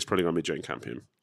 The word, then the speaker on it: Jane Campion: And it would be down to Paul Thomas Anderson and Jane Campion.